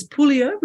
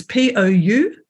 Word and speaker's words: Pouliot: Okay, so it's Pouliot with a silent T. – How do you spell it? – It's Pouliot.